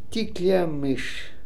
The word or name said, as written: Tikliamish